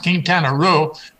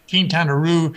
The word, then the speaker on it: Quintana Roo: And Rue, although I think it's really pronounced Quintana Roo, but I mispronounced it Quintana Roo,